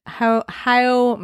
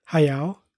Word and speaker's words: Hayao: So tonight we are delving back into the world of Studio Ghibli, say it however you want, and Hayao Miyazaki. – Kind of right, sort of, not really. – How do you say it? – Hayao. – Hayao Miyazaki. I've heard people say Haya.